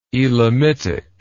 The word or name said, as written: Elamitic